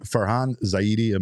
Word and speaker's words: Farhan Zaidi: Bob Nightingale goes on to say, is it Farhan Zaidi? Am I saying that right? – Yes, right.